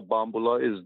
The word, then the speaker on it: bambula: In New Orleans, the rhythm that they call bambula in New Orleans, accent on the second syllable,